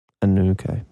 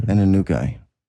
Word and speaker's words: Anunnaki: How do you say Anunnaki? – Fucking around. Anunnaki. – Anunnaki.